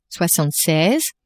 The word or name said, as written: soixante-seize